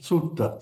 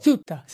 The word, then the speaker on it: Suetta: It's S-U-E-T-T-A. Sutta? Is that how you pronounce it? S-U-E-T-T-A. – Sutta. – Sutta. See, he had it better than I did.